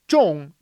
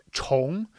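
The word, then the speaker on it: 重: Because so Zhong means heavy, but it can also be pronounced Chong, which means once more or to repeat something.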